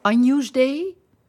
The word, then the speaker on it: Agnus Dei: Dan gaan we naar de laatste ronde met het Agnus Dei. Zeg ik dat goed? – Zeker.